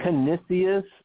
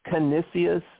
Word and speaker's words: Canisius: Canisius. It's Canisius, C-A, N as in Nancy, I, S as in Sam, I, U, S as in Sam. C-A-N-I-S-U-S. I-S-I-U-S, sorry. So C-A-N, can, I-S, is, can, is. I-U-S-E-S.